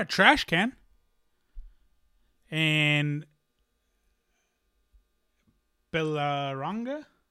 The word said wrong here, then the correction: Berlanga: Did I say his name again? Berlanga.